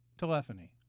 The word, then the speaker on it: telephony: Telephony. Yeah, that's how you pronounce it.